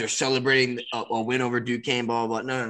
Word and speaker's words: Duquesne: First of all, don't know how it's spelled like that and then pronounced Duquesne.